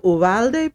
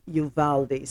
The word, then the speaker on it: Uvalde: If you hear me go from saying Uvalde to Uvalde, please forgive me, because the Uvalde is this pronunciation, of course, is the Latin pronunciation for that town. And of course, we know Texas was a part of Mexico at one point, but the American pronunciation is Uvalde.